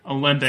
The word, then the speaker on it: Olympe: Olympe, so yeah. – It's just Olymp, you don't pronounce the E.